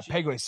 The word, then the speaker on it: Pegues: Um, I can't pronounce his last name. JJ. Pegues, I think it is. – P-E-G. Yeah, Pegues. Yeah.